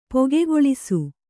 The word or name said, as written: ಪೊಗೆಗೊಳಿಸು